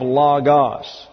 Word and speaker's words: Logos: W-O-R-D, Word. But you go to the Greek and it is Logos. Now, these are omicrons here, which means that they're pronounced like a short English A. Thus, we have Logos.